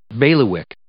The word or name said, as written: bailiwick